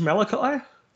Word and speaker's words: Malachi: I'm going to Malik I think it's Malachi. It's spelt a bit different.